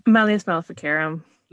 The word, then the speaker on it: Malleus Maleficarum: Malleus Maleficarum.